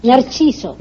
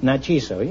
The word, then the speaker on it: Narciso: Narciso. – Narciso.